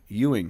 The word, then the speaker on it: Ewing: Alexander Ewing. Is that how you pronounce it? It's like Patrick Ewing.